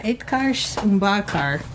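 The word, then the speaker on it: Utkarsh Ambudkar: It's Itkarsh Mbakar. – Oh my God, I said it right. – Let me see it. – Yeah, Itkarsh Mbakar.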